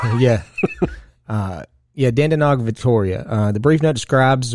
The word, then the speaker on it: Dandenong: That's exactly how they say it, Dandenong.